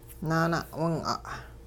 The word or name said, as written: naana'wing'a'